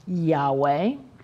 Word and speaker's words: Yahweh: One of the divine names of God is YHWH, which is pronounced Yahweh.